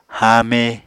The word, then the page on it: haame: Apatani